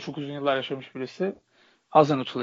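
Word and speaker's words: Hasenhüttl: Bu arada Hazenhüttl telaffuzu tam nasıl abi? Hazenhüttl ya doğru. Doğru mu? Tamam. Doğru doğru.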